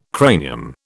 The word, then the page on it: cranium: British English